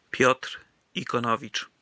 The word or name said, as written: Piotr Ikonowicz